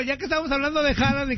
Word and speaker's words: Haaland: Su pronunciación es Haaland. – Haaland, de hecho, ayer me pegó un tirante también con mi esposa hablando de eso.